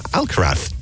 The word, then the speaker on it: Alcaraz: Iga Swiatek and Carlos Alcaraz are amongst... Alcaraz? I said it properly.